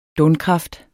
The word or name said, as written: donkraft